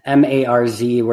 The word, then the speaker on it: Marz: Again, that's Marz, where you can follow him at Tyler Marz on Instagram.